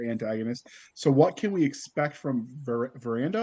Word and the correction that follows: Verita: Is that how you say it? – Verita.